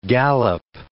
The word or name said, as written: gallop